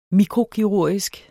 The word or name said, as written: mikrokirurgisk